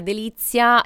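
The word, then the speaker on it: The Lizia: Delizia si scrive THE, quindi l'articolo determinativo inglese, poi Lizia, questo bel gioco di parole.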